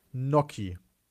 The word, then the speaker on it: Gnocchi: Gnocchi. Ja. – The G is silent, glaube ja, ich. Ja, Gnocchi.